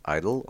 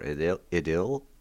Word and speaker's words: Idyll: First, Idyll or Idyll, and I've checked, both of those pronunciations are acceptable in English.